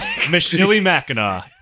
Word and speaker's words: Michilimackinac: and that is the one at Fort Michilimackinac. And Fort Chilimack? – Michilimackinac. And I actually know for sure I am pronouncing this one right.